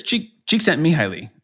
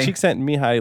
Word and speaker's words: Csikszentmihalyi: I'm pretty sure it's Csikszentmihalyi, right? So Mihai... – Csikszentmihalyi. – Csikszentmihalyi. It's like... whatever, like five syllables in that last name.